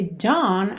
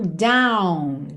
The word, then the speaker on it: dawn: Porque esse dawn a gente tem D-A-W-N. Então, muitas pessoas diriam down, down, que acaba ficando parecido com down, D-O-W-N, baixo, para baixo, ok?